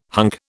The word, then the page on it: hunk: British English